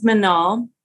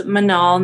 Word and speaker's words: Manal: So, um, my name is Manal. It's pronounced Manal, not Manal or M Anal.